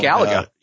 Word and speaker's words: Galaga: Galaga.